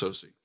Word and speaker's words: Soucy: Years ago, one of my professors, who's since gone to be with the Lord, Robert Soucy, I know it's spelled saucy, but it's pronounced Soucy.